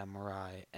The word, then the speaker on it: Mirai: After Isle of Dogs, we have Mirai. I think that's how you say it. – Mirai? – Yeah, Mirai. M-I-R-A-I. And then we got Ralph Breaks the Internet.